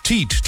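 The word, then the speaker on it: TEED: Teat, Totally Enormous Extinct Dinosaurs, so heißt es ausgesprochen.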